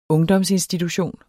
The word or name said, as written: ungdomsinstitution